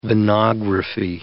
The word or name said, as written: venography